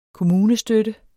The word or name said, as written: kommunestøtte